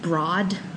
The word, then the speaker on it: Broad: It's spelled like Broad, but it's pronounced Broad.